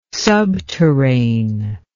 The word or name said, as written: subterrane